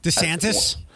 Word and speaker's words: DeSantis: He's DeSantis.